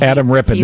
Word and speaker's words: Adam Rippon: Adam Rippon.